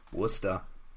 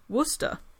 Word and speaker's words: Worcester: Worcester. – Did I say it wrong? – No, you said it right. – Worcester. So it's like rooster with a W. Worcester.